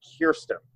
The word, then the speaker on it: Kirsten: You know, just monitoring Twitter, we're at the point now where there are so many candidates that they're actually putting on Twitter the proper way to pronounce their names. It's Kirsten. It's Beto.